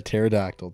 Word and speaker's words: pterodactyl: A pterodactyl, Tiffany. Jesus Christ. – That's how you spell? – Yes. – Pterodactyl? – Yeah, pterodactyl. It's a P. It's a silent P.